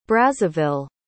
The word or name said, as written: brazzaville